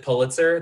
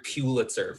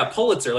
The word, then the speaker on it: Pulitzer: Can I say, I'm so glad that y'all pronounce it Pulitzer the correct way. You have no idea how much I hear Pulitzer from people. – Pulitzer, oh, really? – Right? – Yeah, Pulitzer, like you're pulling it toward yourself.